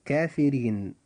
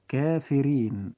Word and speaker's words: كافرين: كلمة كافرين حرف الراء في هذه الكلمة جاء مكسورا، وإذا جاء حرف الراء مكسورا في أي كلمة فوجب ترقيق هذا الحرف فتنطق كلمة كافرين هكذا كافرين. – كافرين.